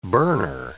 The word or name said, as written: burner